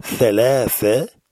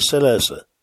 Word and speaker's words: ثلاثة: On fait bien attention à prononcer thalatha, ni thalatha, ni salasa. Thalatha.